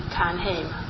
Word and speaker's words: Ternheim: Well, if I would pronounce it in Swedish, it would be Ternheim.